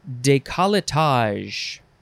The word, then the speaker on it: décolletage: Next is décolletage. Décolletage, or you can say décolletage, or you can say, what? Are we combining things here? Décolletage. You can also say that, décolletage, but that's removing a whole syllable. It is spelled D-E-C-O-L-L-E-T-A-G-E, décolletage.